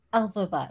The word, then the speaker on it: Elphaba: Elphaba, yes.